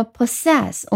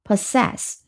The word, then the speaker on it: possess: 那么 possess 我们从读音开始， P-O possess S-S-E S-S possess，那么重音在后面，前面一个 PO 读成 per，后面是很有意思的，两个 S 开头，两个 S 结尾，中间加了一个 E，读成 sess，所以 possess 记住它都是两个 S，前面两个后面两个 P-O-S-S-E-S-S possess。